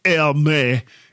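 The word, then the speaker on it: Hermès: I don't think the S is pronounced. Hermes edition with a stupid pricing for Americans.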